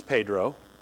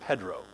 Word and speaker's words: Pedro: Like, for example, do y'all know that if you live in, let's say, Venezuela, and your name is P-E-D-R-O, that name is Pedro, okay? But did you know that if you live in Brazil, that same name, P-E-D-R-O, is pronounced Pedro? Did y'all know that? That the Portuguese pronounce that name Pedro, and the Spanish pronounce that name Pedro.